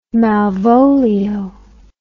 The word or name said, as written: malvolio